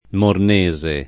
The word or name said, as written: Mornese